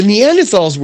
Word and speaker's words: Neanderthal: Neanderthal, which is how you pronounce it,